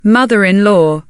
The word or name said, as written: mother-in-law